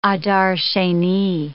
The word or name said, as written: Adar Sheni